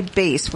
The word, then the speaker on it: bass: How can you say bass when it's B-A-S-S? – Well, English is a funny language.